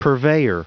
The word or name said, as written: purveyor